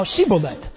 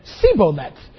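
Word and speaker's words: Shibboleth: And if he said no, then he would say to them, "Say now Shiboleth." But he said, "Siboleth," for he could not pronounce it correctly.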